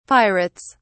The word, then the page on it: pirates: British English